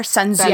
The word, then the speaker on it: Sun Tzu: Do you guys know how to pronounce? I'm, like, embarrassed because everybody reads this, but is it Sun Tzu? Is that how you yes. pronounce the author? Sun Tzu, yeah, The Sun Art Tzu.